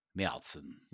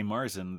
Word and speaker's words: Märzen: And for our German listeners, we understand that it's pronounced Märzen. – Märzen. – Märzen. – And that's important for our American listeners, too, because when you go there, if you say Marzen, they probably will look at you like you have three heads.